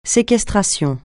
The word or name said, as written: séquestration